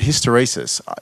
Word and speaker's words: hysteresis: That's how you meant to say the word, hysteresis.